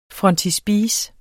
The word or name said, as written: frontispice